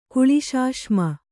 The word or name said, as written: ಕುಳಿಶಾಶ್ಮ